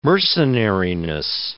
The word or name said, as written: mercenariness